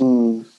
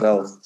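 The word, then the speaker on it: Rauw: Rau Alejandro. – Rau, das W ist einfach stumm, still. – Ja, ist einfach R-A-U. Ja. – Rau.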